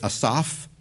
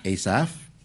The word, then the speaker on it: Asaph: Because the very Holy Spirit that inspired Peter to write 1 Peter was the same Holy Spirit that inspired Asaph, or I heard someone pronounce it Asaph. I will be pronouncing it Asaph.